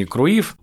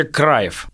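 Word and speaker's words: Cruijff: он же никакой не Круив, он вообще Краев, но в СССР его всегда писали Круив, ну, просто вот транслитом.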